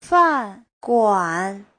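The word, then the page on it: fanguan: fan4guan3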